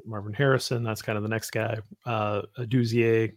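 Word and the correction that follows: Odunze: Is that how you say it? – Adunze.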